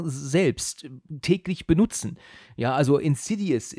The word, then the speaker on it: Insidious: Und genau, wir sind dann am gleichen Abend sogar nach Saarbrücken ins Kino und ja, ich habe den Titel des Namens sogar falsch ausgesprochen, weil ich dieses Wort gar nicht kannte. Ich habe dann Inside Insidious gesagt. – und der, der, Typ hat dann gesagt, ah, Sie meinen Insidious.